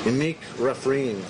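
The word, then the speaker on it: Emek Refaim: I want to say right now I'm in what they call the German colony, and I never say it right in Hebrew. Emek Refraim. Thank you. See, I always get it wrong.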